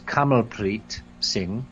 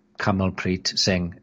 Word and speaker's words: Kamalpreet Singh: Yeah, I want to say first right away, I mispronounced the gentleman's name. That was Kamalpreet Singh, who was interviewing Dr. Carrie Madej, which we just listened to, Kamalpreet Singh.